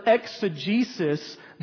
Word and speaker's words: exegesis: E-X-E-G-E-S-I-S.